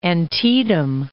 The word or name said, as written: Antietam Creek